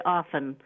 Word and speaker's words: often: How about how we had to learn to say often without a T being pronounced? – Yeah, well, that's not a mistake. The T actually is not pronounced.